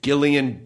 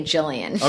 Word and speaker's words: Gillian: It's like Gillian Jacobs. That is the correct hard G, soft J.